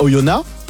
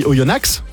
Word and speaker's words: Oyonnax: Ça s'écrit avec un X à la fin. Si tu vas là-bas et tu dis Oyonnax. – Ah oui, mais ça c'est logique. – Tu vas voir ce qui va t'arriver.